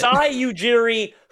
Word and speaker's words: Masai Ujiri: Masai Ujiri, who was the GM of the NBA championship Toronto Raptors team, now trying to do the same in Dallas.